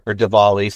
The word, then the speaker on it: Diwali: I'm pretty sure, too, when it comes to Diwali, or Diwali, sometimes it might be pronounced with a V,